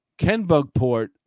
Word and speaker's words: Kennebunkport: He grew up in a gigantic $2 million Kennebunkport mansion and sailed on parents' stunning yacht. – Kennebunkport, isn't it? – Yeah. No, go ahead. Sorry. You said it right, actually.